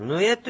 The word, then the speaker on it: ぬえ: えぬえおけれやで、このぬえという言葉がちょっと発音が難しいのではないかと思います。で、ちょっと仙国さん、このぬえというのを私が言った後に発音してみてください。カタカナで書きますと、ぬいえとかなってます。いの次にちっちゃいえが、ついてます。ぬえという発音です。ぬいえではないんですね。